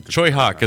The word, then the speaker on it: Tsui Hark: Choi Hawk is the way you're supposed to say his name.